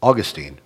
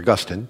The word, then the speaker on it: Augustine: When the Roman Empire was falling, Augustine, or Augustine, depending how you'd like to pronounce that, you have freedom of pronunciation here, it's okay.